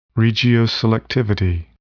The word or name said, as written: regioselectivity